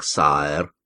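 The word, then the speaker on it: sire: В Англии короля называют сайр, произносится именно так, сайр.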